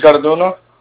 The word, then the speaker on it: Garduno: My name is Luis Garduno.